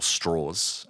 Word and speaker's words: Strauss: But the character he was playing, Strauss or Straws, as he pronounces it, which we can kind of get to that character detail in a bit.